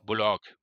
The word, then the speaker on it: Bullock: Und der hat sich jetzt offiziell in einem Interview, ich weiß nicht, ob es Postgame war oder sowas, hat er gesagt, er wird Bullock ausgesprochen. Nicht Bullock, so. sondern ah, das okay, O. Also das die, Betonung, o. die Betonung auf das O. – Genau. – Bullock.